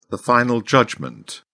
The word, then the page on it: final judgment: British English